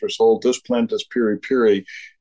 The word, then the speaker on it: piri piri: You mentioned in the piece there's something called piri piri. Am I saying it right? – Right. Okay. Yes.